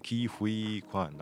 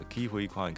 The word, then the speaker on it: Ke Huy Quan: 颁奖典礼完的会后呢，接受记者采访的时候，也有记者呢先跟他确认说：“ 你的名字是念 Ke Huy 还是 Ke Huy Quan？” 然后他本人有自己发音了一下，不然很多人会念错。好，还有另外一个颁奖者是 Michelle Yeoh。好，所以分别是这个 Ke Huy Quan，他是颁奖给这个 Robert Downey Jr.，那 Michelle Yeoh 就是杨紫琼嘛，他是颁奖给 Emma Stone。这个还蛮刚好的，Ke Huy Quan 跟 Michelle Yeoh 都是演这个《妈的多重宇宙》的。